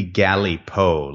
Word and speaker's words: Galipaux: So it's supposed to be Galipaux, like G-A-L-I-P-A-U or P-A-U-X, I forget, which is a fairly common French-Canadian last name.